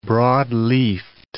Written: broad-leafed

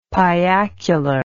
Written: piacular

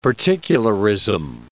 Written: particularism

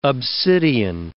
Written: obsidian

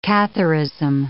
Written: Catharism

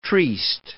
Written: triste

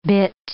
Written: bitt

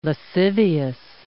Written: lascivious

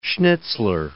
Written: Schnitzler

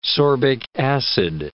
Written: sorbic acid

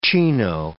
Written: chino

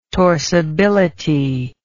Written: torsibility

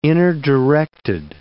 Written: inner-directed

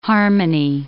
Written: harmony

